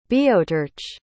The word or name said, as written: boeotarch